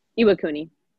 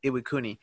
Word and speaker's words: Iwakuni: The place that you said you lived in, how did you say it? Ikuani? No, wait. – Iwakuni. – Iwakuni.